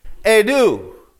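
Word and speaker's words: edu (ἰδού): And it's pronounced edu. I want you to practice with me. It's kind of like that French adu, but edu. Say it with me, edu.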